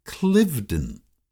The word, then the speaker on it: Cliveden: Tom tells me, by the way, that the Chu home in Germantown is often pronounced Cliveden, just like its namesake, the huge manor house in Buckinghamshire, England, even though the filly Cliveden is found on Cliveden Street.